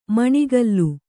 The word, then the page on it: ಮೊಣಕಾಲ್: moṇa kāl